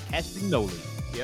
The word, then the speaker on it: Castagnoli: We saw the wrestling return of Cesaro, or shall I say Claudio Castagnoli? Am I saying that right? – Castagnoli. Yep, you're right.